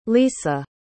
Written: lisa

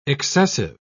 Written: excessive

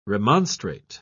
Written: remonstrate

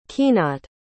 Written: keenat

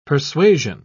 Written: persuasion

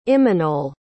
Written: imanol

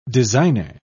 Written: designer

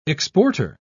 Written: exporter

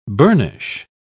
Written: burnish

